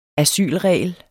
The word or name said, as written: asylregel